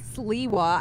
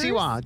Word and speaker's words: Siwa: It's not Sliwa. I even gave you the answer. I said her fans were Seawanators. – Siwa. JoJo Siwa.